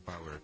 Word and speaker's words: PWR: Называется он Ear Power, но слово Power пишется из одних согласных, P-W-R.